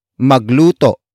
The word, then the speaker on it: magluto: magluto is spelled as m a g l u t o note the glottal stop at the end